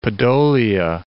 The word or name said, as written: Podolia